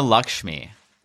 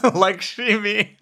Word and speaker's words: Lakshmi: How do you pronounce her name? – Padma Lakshmi. – Oh, Lakshmi. Okay. – Yeah. – I think I added an extra I on it, like it was sashimi.